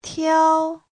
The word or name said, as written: tiao1